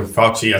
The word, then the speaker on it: Fauci: Like, I mean, this might be an example, but I remember Dr. Fauci, I think this is how you say his name. – Yeah.